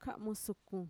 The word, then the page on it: ka'musukun: Mixtec